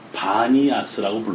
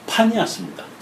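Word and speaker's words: Banias: 그러니까 아랍어에는 그래서 여러분들 판 신전이 있는 가이샤라 빌랄 지역을 바니아스라고 불러요. 오늘날 원래는 파니아스입니다. 파니아스인데 얘네들은 피 발음이 안 돼서 마니아스 이렇게 부르는 것처럼 아, 부톨레미도 돌레마이라는 이름으로 전해진다.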